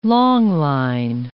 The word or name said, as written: longline